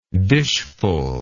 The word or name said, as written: dishful